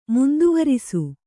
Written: ಮುಂದುವರಿಸು

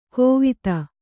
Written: ಕೋವಿದ

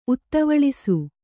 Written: ಉತ್ತವಳಿಸು

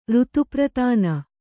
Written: ಋತುಪ್ರತಾನ

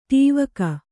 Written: ಡವಿಗೆ

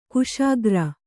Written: ಖುಷಿಗಾರ

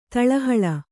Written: ತಳಹೊಳ

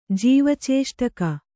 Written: ಜೀವಚೇಷ್ಟಕ

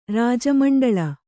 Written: ರಾಜಮಂಡಲ

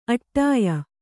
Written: ಆಢ್ಯೆ

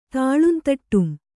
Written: ತಾಳುಂತಟ್ಟುಂ

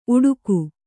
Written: ಉಡುಗು

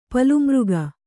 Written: ಪಲುಮೃಗ

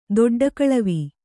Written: ದೊಡ್ಡಕಳವಿ

